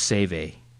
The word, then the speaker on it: Cewe: This, I think it's pronounced Cewe, C-E-W-E, is like the biggest printer in Germany, I think.